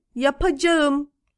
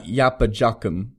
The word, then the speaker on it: yapacağım: Ödev yapacağım. Yapacağım. Oh. Yap. – Okay. – Yapmak. Yap. Yapacağım. – So not yapacakım. – No. That K turns into the silent G.